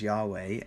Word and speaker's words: Yahweh: And probably the closest we could come to pronounce it in English is Yahweh.